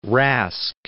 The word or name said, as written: Rask